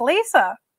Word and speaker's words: Liza: It is spelled Liza or Alyssa, but mm-hmm. it's pronounced Lisa.